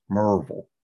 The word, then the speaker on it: Maryville: And in Nashville, I was from Maryville, Tennessee, which properly pronounced in Maryville is Merville. And you move to Nashville and people in Nashville say M-A-R-Y as Mary.